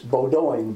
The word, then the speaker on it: Bowdoin: P-I-C-K-E-R-I-N-G? – Yeah, P-I-C-K-E-R-I-N-G. – Okay, and Bowdoin is spelled B-O-W-D-E-N? – No, D-O-I-N. B-O-W-D-O-I-N. Some people call it, think it's Bowdoin, but it's Bowdoin.